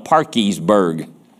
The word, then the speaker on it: Parkersburg: Of course, they don't call it Parkersburg. They call it Parkiesburg. I kid you not. I remember first time I heard that. "How you spell that?" "Oh, hush." But that's how they pronounced it.